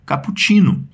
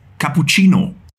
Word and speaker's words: cappuccino: Em português, a gente tem uma certa tendência a pronunciar esses os em final de palavra, quase como se eles fossem us. A nossa palavra ficaria, então, cappuccino. Em alemão, esse o vai continuar intacto, e isso cria uma pequena diferença de pronúncia.